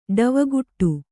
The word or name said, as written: ಡವಗುಟ್ಟು